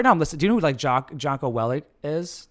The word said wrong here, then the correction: Jocko: Jocko Willink? – Yeah.